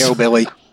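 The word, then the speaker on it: Nobiliary: It's actually the Foursquare Nobel Uri, as I can say it correctly, maybe, once. – Nobilly.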